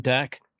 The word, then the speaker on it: Dak: You know, so um, did I get his name right? Dak. Dak. I think yeah, it's Dak.